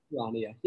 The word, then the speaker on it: Charania: Is it Sham Charania? Do you know how to pronounce his last name? – Charania, yep.